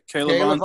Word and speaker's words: K'Lavon: K'Lavon, is that how you say it correctly? – Yes. – Yep, K'Lavon Chaisson.